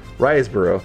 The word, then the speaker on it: Riseborough: how do you pronounce her name? – Riseborough. – Thank you. That's actually how you do it.